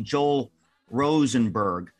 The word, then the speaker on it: Joel Rosenberg: It's not Roel Josenberg. It's actually Joel Rosenberg.